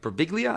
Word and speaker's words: Birbiglia: And then we'll be talking about Don't Think Twice, directed by Mike Birbiglia.